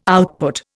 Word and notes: output: Indian

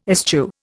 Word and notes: eschew: Indian

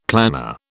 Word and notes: planner: British English

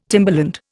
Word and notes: timberland: Indian

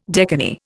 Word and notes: decani: Indian

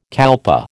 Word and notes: kalpa: American English

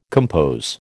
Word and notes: compose: American English

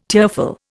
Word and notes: tearful: Indian

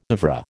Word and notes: oeuvre: American English

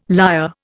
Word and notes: lyre: Indian

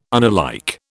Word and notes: unalike: British English

